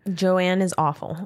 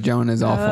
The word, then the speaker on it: Joan Is Awful: So it was Joan is awful.